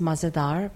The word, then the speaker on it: Mah Ze Dahr: So the name of the company is Mah Ze Dahr Bakery.